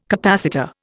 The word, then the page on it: capacitor: Indian